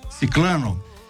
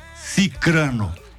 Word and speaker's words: cicrano: É fulano, beltrano, cicrano. Muitas pessoas pronunciam ciclano, infelizmente, né? – Ah, não é ciclano. – Não existe ciclano na língua portuguesa. – É cicrano.